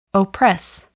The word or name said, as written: oppress